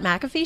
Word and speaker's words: McAfee: He broke the news of his involvement during his weekly appearance on the Pat McAfee show. Did I say his name right? – That is correct. McAfee? Yes, Pat McAfee.